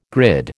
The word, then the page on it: grid: American English